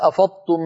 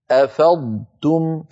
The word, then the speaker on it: أفضتم: أفضتم صار النطق كأنه طاء مشددة، وليس الأمر كذلك، لابد من أن ننطق ضادا يتلو، ضادا مفخمة مستطيلة يتلوها تاء مرققة مستفلة، هكذا. فإذا أفضتم، أفضتم، كذلك الأمر في سورة، في سورة النور لمسكم فيما أفضتم، أفضتم، فصوت الضاد شيء وصوت التاء بعدها شيء آخر.